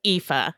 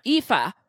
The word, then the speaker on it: Aoife: Yeah, it's pronounced Aoife. – Aoife. For setting us straight.